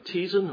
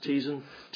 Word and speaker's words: Teston: Among anti-slavers testifying were Reverend James Ramsay, 14 years rector of St. Kitts, and now vicar of Teason, or spelt Teston, but pronounced Teason, Teason in Kent.